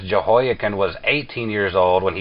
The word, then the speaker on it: Jehoiakim: Now this first question, I will not pronounce this name correctly. Tell me how to pronounce this gentleman's name ahead of time here. – Well, it's a Jewish king. His name is Jehoiakim.